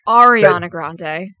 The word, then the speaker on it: Ariana Grande: I got called out last time for saying her name wrong, apparently. I said Ariana. I'm sorry. Ariana Grande.